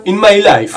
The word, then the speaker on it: In My Life: In My Life.